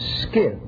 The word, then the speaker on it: Scyth: The name Scyth, pronounced Skith.